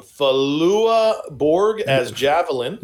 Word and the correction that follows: Flula Borg: Yeah, Flula Borg.